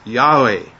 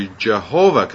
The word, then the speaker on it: Yahweh: That's not a correct pronunciation of the name. And nowadays, we want to say it as Yahweh.